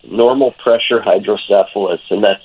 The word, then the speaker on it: hydrocephalus: Normal pressure hydrocephalus.